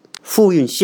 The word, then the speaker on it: 富蕴: 我在网上查了一些资料哈，这个对于这科克托尔这个地方啊，它实际上呢是属于新疆北部，有一个地方叫富汶富蕴。富蕴县啊，这是这这个这个字啊，这个蕴叫蕴蕴酿的蕴，蕴藏的蕴哈，这个同一个发音。